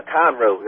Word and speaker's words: Conroe: The deep south, Conroe, that's it.